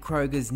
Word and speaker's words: Kroeger: It turns out that we've been saying Nickelback frontman Chad Kroeger's name incorrectly for years and years. Apparently, it's pronounced Kroeger as in Freddy Krueger.